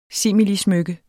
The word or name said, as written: similismykke